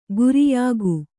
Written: ಗೋರಿಯಿಕ್ಕು

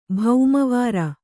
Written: ಭೌಮವಾರ

